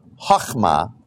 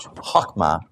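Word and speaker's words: chokhmah (חָכְמָה): The word that has been used in the English language to translate a certain Hebrew word, which is pronounced this way, chokhmah, is that dot under the H is for a hard kind of H, chokhmah.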